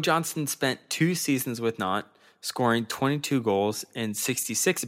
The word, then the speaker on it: Nantes: Yes, and I believe I crushed that pronunciation. – Nantes? Yes, that is correct. That is the right way to say it.